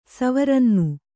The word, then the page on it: ಸವರನ್ನು: savarannu